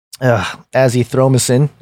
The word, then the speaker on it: azithromycin: Plus, HCQ was administered without azithromycin.